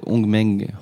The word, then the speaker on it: Hongmeng: Ça s'appelle Hongmeng OS. – Excuse-moi. Bien joué. – Pas mal. Fallait prononcer le H.